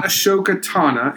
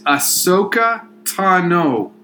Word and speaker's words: Ahsoka Tano: It is not Ahsoka Tana. It is Ahsoka Tano.